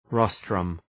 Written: rostrum

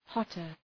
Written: hotter

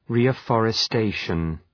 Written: reafforestation

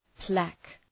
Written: plaque

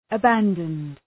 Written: abandoned

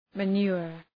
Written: manure

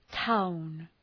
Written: town

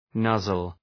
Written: nuzzle

